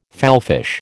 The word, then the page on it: fallfish: American English